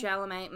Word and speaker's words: Chalamet: That Timothy, how do you say his name? Is it Chalamet? – Chalamet, mm-hmm.